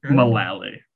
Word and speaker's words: Mullaly: Mullaly.